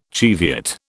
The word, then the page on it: Cheviot: British English